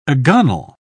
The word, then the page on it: agonal: British English